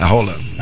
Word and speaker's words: Haaland: O Gabriel Magalhães vai marcar o Roland, porque o Haaland pronuncia Roland. Ele se apresenta assim, né? A pronúncia é Roland.